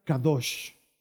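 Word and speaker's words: kadosh (קדוש): Esta palabra en hebreo se pronuncia kadosh. Se pronuncia kadosh que literalmente significa santo.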